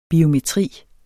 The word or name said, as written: biometri